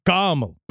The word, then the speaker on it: Carmel: And there was I'm from Mount Carmel. And it's called Mount Carmel. – Yeah. – And the pronunciation's with the car up front. It's not Mark Carmel. This isn't a town made out of candy.